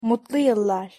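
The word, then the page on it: mutlu yillar: Turkish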